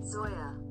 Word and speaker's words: Zoya: Zoya. – Okay, it's Zoya. I said Zula.